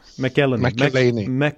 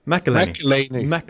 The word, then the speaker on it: McElhinney: McElhinney. – Sluta nu. Ja. Sluta. Du sa det ju rätt nyss. – Ja, McElhinney.